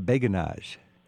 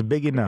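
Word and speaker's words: Béguinage: So, but it's a Beijenage. – It's a Beijenage. – It's a Beijenage, yes.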